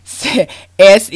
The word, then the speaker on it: Semmelweis: When Onassis, and I'm probably not pronouncing that right, Onassis is I-G-N-A-Z. I'll just spell it to you. Semiroz, and probably didn't pronounce that right. Y'all excuse me here. Semmelweis, because I never seen these names, but he was a Vietnamese doctor.